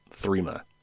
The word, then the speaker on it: Threema: Anyway, there was another one that I don't often talk about called Threema, and that's spelled out like T-H-R-E-E and then M-A, Threema.